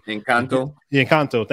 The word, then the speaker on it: Encanto: I mean, even I think about Encanto, if I said that correctly. – Encanto.